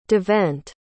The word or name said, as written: devent